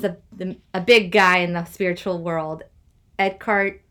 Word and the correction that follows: Eckhart Tolle: He calls himself Eckhart Tolle.